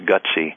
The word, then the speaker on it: GTSY: One of them is called Good to See You, and the acronym is GTSY, pronounced Gutsy.